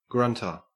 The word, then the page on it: grunter: Australian English